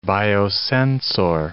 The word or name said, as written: biosensor